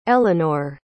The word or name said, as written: eleanor